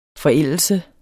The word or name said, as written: forældelse